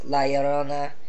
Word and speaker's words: La Llorona: And, like, the first week was Pet Sematary, second week was Hellboy, third week is La Llorona, and then fourth week is Avengers.